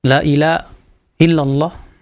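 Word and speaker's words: la ilaha illallah (لا إله إلا الله): Adapun la ilaha illallah, maka pengucapan yang benar seperti itu, Bu. La ilaha illallah. Karena ilah itu artinya sesembahan. Adapun yang baca ilaha, ila, la ilaha illallah, ya, mungkin karena ketidakfasihan dia, dia tidak faham, maka itu jelas merubah.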